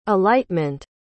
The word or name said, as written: alightment